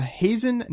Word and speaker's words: Hazen: And last week, you gave a shout-out to Hazen, North Dakota.